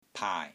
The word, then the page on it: PAAI3: Cantonese